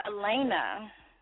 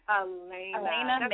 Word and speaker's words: Alayna: First, you need to correct me on your name. How do I say it? – It is Alayna. – Alayna Maberry.